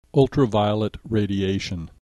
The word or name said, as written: ultraviolet radiation